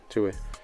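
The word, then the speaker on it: Tuohy: Is that how you say it? – Tui? Yeah, Tui.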